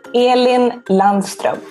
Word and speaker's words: Elin Landström: Elin Landström.